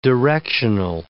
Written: directional

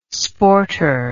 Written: sporter

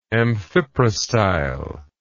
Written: amphiprostylar

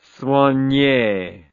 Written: soignee

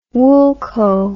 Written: woolco

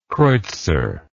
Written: kreutzer

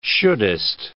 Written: shouldest

